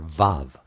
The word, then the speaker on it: Vav: How to pronounce the Vav letter in Hebrew? I would say V or Vav.